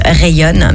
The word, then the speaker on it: Ryon: Ce groupe Rayon, si vous ne connaissez pas Rayon, ça se prononce Rayon, mais ça s'écrit R-Y-O-N.